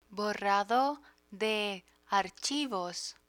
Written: borrado de archivos

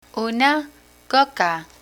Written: una coca

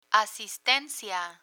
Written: asistencia

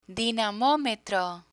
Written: dinamometro

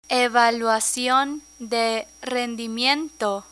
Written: evaluacion de rendimiento

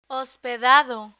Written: hospedado

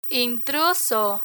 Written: intruso

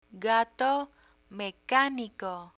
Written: gato mecanico

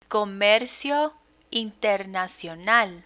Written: comercio internacional

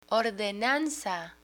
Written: ordenanza

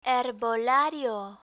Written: herbolario

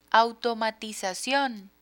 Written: automatizacion

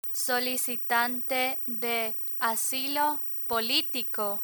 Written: solicitante de asilo politico

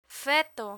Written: feto

